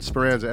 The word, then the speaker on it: Speranza: Find me on Instagram at Damien, D-A-M-I-E-N, S-Comedy. And on Facebook, it's Damien Speranza, S-P-E-R-A-N-Z-A.